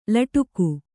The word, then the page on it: ಲಟಿಕಿ: laṭiki